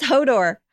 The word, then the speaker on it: Hodor: Oh, sorry. I said it wrong. – Hodor? – It's Hodor.